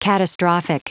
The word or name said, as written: catastrophic